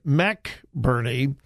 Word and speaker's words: McBurney: And yeah, it's spelled the same way, B-U-R-N-E-Y, McBurney.